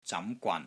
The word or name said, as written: ZAM2 GWAN3